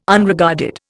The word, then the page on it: Unregarded: Indian